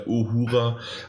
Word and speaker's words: Uhura: oder mit Duhur, äh, Uhura äh, an und so weiter.